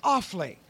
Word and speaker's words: Offaly: What county are you in? – We're in Offaly. – Offaly. Okay, that's... Yes. – O-F-F-A-L-Y.